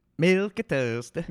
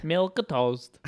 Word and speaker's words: milquetoast: Okay, it came from Safari. It was what Merriam-Webster, like, pronouncing it, like, saying the name of it. – Milquetoast. – Milquetoast. There you go.